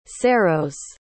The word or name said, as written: ceiros